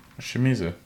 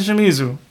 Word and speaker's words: Shimizu: Shimizu. – That's Shimizu.